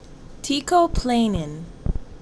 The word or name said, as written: Teicoplanin